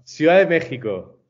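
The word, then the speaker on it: Ciudad de México: My guy is out in Mexico, Mexico City, Ciudad de Mex. Is that how they say it? – No, not at all. No, Ciudad de Mexico.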